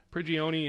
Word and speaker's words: Prigioni: You played with Pablo, assistant coach, Prigioni. Am I saying the name right? – Yeah. – Prigioni in L.A.